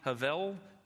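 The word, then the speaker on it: hevel (הבל): But the word hevel or hevel, think of the word level and just make it an H on the front. So I think probably the Jewish pronunciation is probably hevel.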